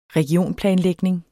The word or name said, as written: REGIONSPLANER